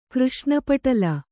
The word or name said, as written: ಕೃಷ್ಣಪಟಲ